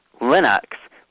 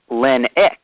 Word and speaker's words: Linux: It's Linux, pronounced Linux. Rhymes with Kinix or Cynix.